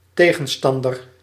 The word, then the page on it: adversaire: [œ̃n‿ad.vɛʁ.sɛʁ]; France